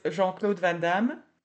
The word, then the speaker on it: Jean-Claude Van Damme: Well, the main actor in the movie is Jean-Claude Van Damme. Tell me if I'm pronouncing him correctly. Then. – So it's Jean-Claude Van Damme. – Yeah. – You're very close.